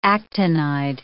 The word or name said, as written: actinide